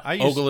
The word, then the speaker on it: ogle: No, oogle is wrong. Ogle is right.